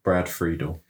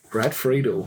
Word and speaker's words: Brad Friedel: Brad Friedel. – Brad Friedel.